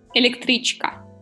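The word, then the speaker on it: электричка: yeah How do I say it? – Electrichka. – You can do it.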